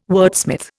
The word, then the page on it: wordsmith: Indian